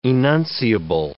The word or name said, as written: enunciable